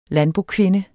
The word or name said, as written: landbokvinde